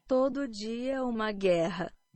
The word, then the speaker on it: Todo dia uma guerra: Todo dia uma guerra. – That is a better pronunciation. That's a Portuguese pronunciation.